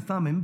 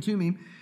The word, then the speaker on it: Thummim: And we would say Urim and Thummim, but they don't pronounce the THs there. So Urim and Thummim,